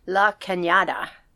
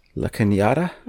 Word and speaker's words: La Cañada: La Cañada, Flint Ridge, California. – La Cañada? – Mm-hmm.